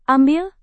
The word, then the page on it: ambil: indonesian